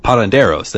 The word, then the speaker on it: parranderos: The party will go on for about an hour or two, after which the residents will join the parandos. Um, I feel like parranderos. There we go. I was like, I feel like I missed a syllable in there.